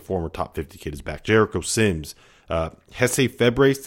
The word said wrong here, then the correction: Jace Febres: did I say that right, Scott? – Jace Febres, I believe.